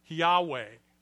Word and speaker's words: Yahweh: When Lord is spelled in all capital letters, it denotes God's name, which in Hebrew is typically spelled out Y-H-W-H, and is pronounced, if there is a pronunciation for it, Yahweh.